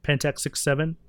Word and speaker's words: Pentax 6x7: So it'll say Pentax 6X7, but you pronounce it just 6.7. Pentax 6.7. And then the second one they came out with is it has no X.